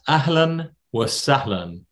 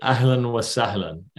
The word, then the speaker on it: Ahlan wa sahlan: It's Ahlan wa sahlan. So A-H-L-A-N wa W-A and then sahlan, S-A-H-L-A-N. So it rhymes a little bit. Ahlan wa sahlan.